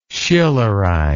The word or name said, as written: Schillerize